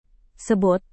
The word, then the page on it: sebut: indonesian